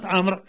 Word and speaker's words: عمرو: ب: عندنا بعض الكلمات تحذف منها بعض الحروف إملائيا، ولكن في الكتابة العروضية لا بد من إثباتها، مثل كلمة عمرو. فكلمة عمرو عندنا الواو تكتب ولا تقرأ، وكذلك في الكتابة العروضية تكتب لا تكتب.